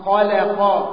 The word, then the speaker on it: خلق: وانتبه من كلمة خلق لأن كلمة خلق فيها إشكالية كبيرة، هذه من الكلمات القرآنية التي يخطئ فيها كثير من الناس عند نطقها. ليه؟ المشايخ ينبهون ويقولون لأن اللام هنا وقعت بين مفخمين، خاء مفخم، لام مرقق، قاف مفخم، وقعت فين؟ في النص، وقعت بين مفخمين. فكثير من الناس يقول خلق خلق خلق، غلط، خلق خلق.